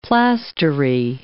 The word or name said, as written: plastery